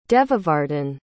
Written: Devavardhan

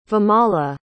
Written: vimala

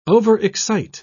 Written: overexcite

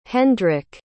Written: Hendrik

